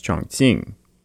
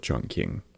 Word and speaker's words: Chongqing: which should be pronounced Chongqing, but all the old books always call it Chongqing.